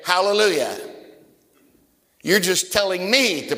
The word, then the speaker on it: hallelujah: It's kind of like the word hallelujah. That's the way we pronounce it. Hallelujah. There's another way to say it. Halal Yi Yah. Halal is the Hebrew word for praise. Yah, J-A-H, is a contraction of Jehovah or Lord.